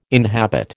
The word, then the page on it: inhabit: American English